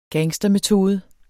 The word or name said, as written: gangstermetode